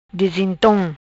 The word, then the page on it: Disintom: Patua